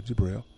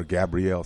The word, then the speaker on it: Gabriel: When you email him for Brother Jabril, he spelled G-A-B-R-I-E-L, Brother Jabril, or Gabrielle, some pronounce it.